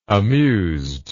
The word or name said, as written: amused